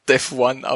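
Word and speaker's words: Devuan: Der größte Takeaway von dem Ding, dass es Dev One ausgesprochen wird.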